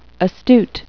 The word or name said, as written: astute